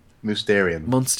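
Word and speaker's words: Mousterian: Obviously, Monsterian tools. I think that's Mysterian. Really cool. What? – Mysterian.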